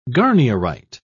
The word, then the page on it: garnierite: British English